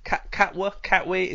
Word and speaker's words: Katwe: Just coming back to the film briefly, are you sure you've actually pronounced the title correctly? Because it sounds like Katwe. – That is the Ugandan pronunciation.